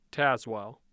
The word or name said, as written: Tazewell